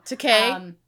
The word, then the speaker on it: Takei: Takei.